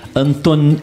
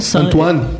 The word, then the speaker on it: Antoine: 1900 nasceu o autor de Pequeno Príncipe Anton... Anton... – Antoine.